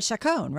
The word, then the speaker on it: Chacon: But Chacon, right? – Correct.